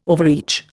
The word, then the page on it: overreach: Indian